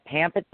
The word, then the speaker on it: Pampa: Uh, yeah, Pampa, Texas, which is in Gray Texas.